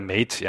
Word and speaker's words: Mate: Also das Mate 10, aber nicht in der Light-Version natürlich. – Heißt das Mate? Oder Mate, ja, ja, Mate soll das heißen.